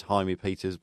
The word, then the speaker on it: Jaime Peters: Just to let you know, some of you people might have thought that I had incorrectly pronounced Jaime Peters, but it actually is Jaime Peters,